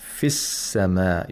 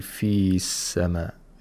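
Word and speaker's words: Fis-sama (في السماء): So you say, Fis-sama. You don't say fi-sama. It's impossible. Mm-hmm.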